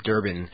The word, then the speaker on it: Durban: sparkling beaches of his native Durban. Did I pronounce that correctly, Craig? – Yes, that's right.